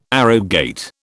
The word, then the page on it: arrogate: British English